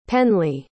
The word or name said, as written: penley